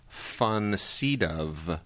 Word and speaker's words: von Sydow: When reading about the supporting actor race, I found out the correct way to pronounce Max von Sydow's name. His last name should be pronounced von Sydow.